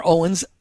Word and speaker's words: Owens: All right, again, we're talking to Dina Dwyer Owens, a co-chair of the Dwyer Group.